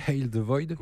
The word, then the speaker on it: Hail the Void: J'ai bien prononcé Hail the Void. – Ouais. – Tu vois le. – Ouais, Void, hop.